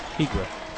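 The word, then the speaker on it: Igre: He said Igra. Okay, well, I'll take his word. – I didn't hear him. What did he say? – Igra. For the last name pronunciation there. It's I-G-R-E. Igra.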